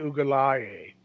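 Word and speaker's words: Uiagalelei: They bench Clemson quarterback at the ACC Championship Saturday night, DJ Ugalale. And thank you for not correcting my pronunciation. – Bonus points. I was going to say that was right.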